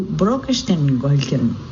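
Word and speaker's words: Brokkestøylen: Sætre is spelled S-E-T-E-R. On the first stretch of my six-day adventure, I drove from Oslo to Brokestengolken. It's B-R-O-K-K-E-S-T-O with a slash through it, Y-L-E-N.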